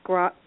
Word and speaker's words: scrying: Photo scrying? – Scrying.